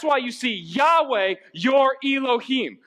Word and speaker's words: Elohim: Man, I hate when I mispronounce that word. Elohim? Thanks. I say Elohim sometimes, don't I? And it's wrong. Thank you. Okay. Nathan just rebuked me.